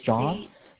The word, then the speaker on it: Jean: It's John, but it's spelled the French way. J-E-A-N.